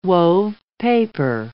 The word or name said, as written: wove paper